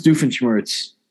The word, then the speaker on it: Doofenshmirtz: Doofenshmirtz? – Dr. Heinz Doofenshmirtz.